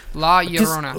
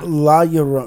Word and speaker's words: La Llorona: Okay, how do you say it again? – La Llorona. – La Llorona. Because I'm learning Spanish. – Because the LL is a Y.